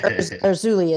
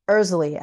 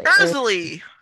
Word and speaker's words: Erzulie: So I used to say Erzulie as well, because that's what it looks like, but it's Erzulie actually. – Erzulie. Yeah. All right. – So Erzulie is,